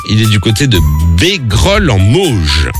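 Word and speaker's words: Bégrolles-en-Mauges: J'espère bien le prononcer, c'est dans le 49. Salut François. – Bonjour la suite. – Coucou, salut François. – Salut le François, c'est bien ça, c'est Bégrolles en Mauges.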